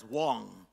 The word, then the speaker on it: Wang: My name is Solomon Wang, pronounced as Wong. It's W-A-N-G, pronounced as W-O-N-G. And if you say Wang, that really would be Wong, all right?